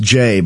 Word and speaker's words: Jeh: Yeah, and his name is actually pronounced Jay, but it's spelled J-E-H. Jeh.